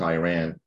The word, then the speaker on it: Iran: I grew up in Iran, and I pronounce it Iran because I ran away.